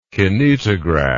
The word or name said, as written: kinetograph